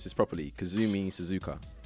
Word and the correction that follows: Kazumi Suzaku: No, it's Kazumi Suzaku.